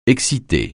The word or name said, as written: exciter